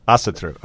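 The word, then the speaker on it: Ásatrú: Okay, well, Hilmar is the high priest, and again, we've got to talk about the pronunciation of, is it Asatru? – Yes, close enough again. – No, how do you say it? – I would say Asatru. – Asatru, okay.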